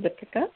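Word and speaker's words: Dipika: Hi, this is Deepika.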